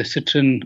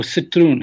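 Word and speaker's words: Citroën: We're talking the citron or citroon. How do you say citroon? – It? Citroon. – French. Yeah. – Oh, citroon. – Yes.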